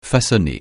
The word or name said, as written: façonner